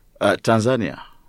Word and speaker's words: Tanzania: So you're from East Africa, you speak Swahili, you're not from the country south of Kenya, but how do you pronounce it? Is it Tanzania or Tanzania? – Tanzania.